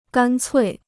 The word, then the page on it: 干脆: gān cuì